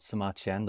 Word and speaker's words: Sima Qian: His name is Sima Qian, which rhymes with Sima Tian, and I like history.